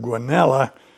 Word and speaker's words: Guanella: Guanella Pass is the local pronunciation, even though there is a double L. There's some controversy on the internet about how to pronounce that word Guanella, but in Colorado, it's Guanella Pass.